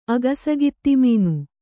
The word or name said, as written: ಅಗಸಗಿತ್ತಿಮೀನು